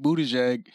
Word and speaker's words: Buttigieg: This nigga Pete Buttigieg. This nigga got butt in his name. – He got booty in his name. – Booty in his name. Booty, but it's spelled butt. Buttigieg.